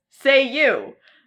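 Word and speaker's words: Ceiu: But it's C-E-I-U, letter U. But conveniently, it's pronounced like the words say you.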